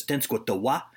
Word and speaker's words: Tenskwatawa: His Shawnee name is Tenskwatawa. I'm told you punch the last syllable in Shawnee, but Americans just call him the Shawnee Prophet because that's a lot easier for them to say and spell.